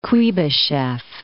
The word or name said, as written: Kuybyshev